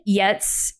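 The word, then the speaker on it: Giertz: So, Simone Yetz is how it's pronounced, but it's spelled Simone, S-I-M-O-N-E, G-I-E-R-T-Z.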